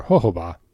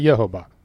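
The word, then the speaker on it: jojoba: I can go with ho-ba or ho-ho-ba, but not yo-ho-ba. – Or yo-ho. – You could always buck the trend and go hard J, Jojo-ba.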